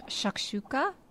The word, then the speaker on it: shakshuka: John, Faith absolutely loves the Middle Eastern dish shakshuka.